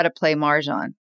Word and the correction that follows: mahjong: okay, how do you say it? – Mahjong. mahjong. You teach me how to play mahjong.